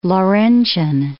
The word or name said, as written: Laurentian Mountains